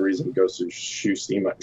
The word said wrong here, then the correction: Tsushima: Shush, I can't say that right now. – T- Tushima. – Shushima. Tushima. Uh, Tushima.